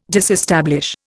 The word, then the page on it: disestablish: Indian